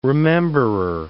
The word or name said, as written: rememberer